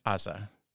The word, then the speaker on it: Haza: Everyone, please welcome Javier Diaz Haza. I say it correctly? – Haza, yeah. – Yeah. How many people say Haza? – Most people say Hach.